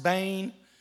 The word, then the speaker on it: ben: The Hebrew word is ben. It looks like Ben, but it's pronounced bane.